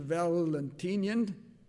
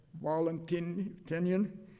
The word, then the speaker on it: Valentinian: As a young man, Theodosius followed in his father's military footsteps, and as a general, he won some important battles under, and I'll use the English pronunciation, Valentinian, rather than, as the Latins would say, Valentinian.